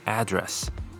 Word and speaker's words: address: 那么它当名词的时候，念作 address，意思是地址。